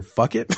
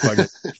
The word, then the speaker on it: Fugit: Is it Fugit or Fuck It? No, it's like Fugit.